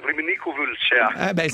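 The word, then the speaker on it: Râmnicu Vâlcea: Allora, tu sei stato in questo villaggio, lo conosci, Ramniciu Valcea, è questa la giusta pronuncia? – No, la pronuncia esatta è Ramniciu Valcea.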